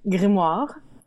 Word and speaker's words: grimoire: The proper French way to pronounce it would be grimoire. And I can do that because I actually speak French.